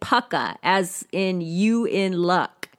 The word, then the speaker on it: Pukka: Puka, which is actually Pukka. I definitely was saying it wrong, which I had the sneaking suspicion I was saying it wrong, but I just carried on because I think Puka sounds funny. but Pukka T. I have since learned that that is the proper pronunciation thanks to the lovely Gemma Hardy on Facebook.